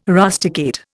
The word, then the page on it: rusticate: Indian